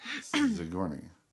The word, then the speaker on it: Sigourney: They probably just saw her name in print and didn't know to pronounce it Sigourney. – Yeah. You say it with a Z. – Sigourney.